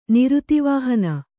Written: ನಿರುತಿವಾಹನ

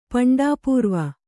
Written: ಪಂಡಾಪೂರ್ವ

